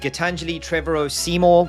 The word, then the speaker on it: Gitanjali Trevorrow-Seymour: I am sitting today with Gitanjali Trevorrow-Seymour.